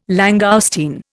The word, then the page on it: langoustine: Indian